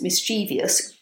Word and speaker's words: mischievous: But surprisingly, the pronunciation of the word with four syllables, mischievous, does go back to the 15th century when that was a common spelling.